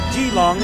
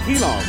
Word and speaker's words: Keelung: That's spelled K-E-E-L-U-N-G, which is pronounced Jilong, and not what a lot of people might say is Keelong.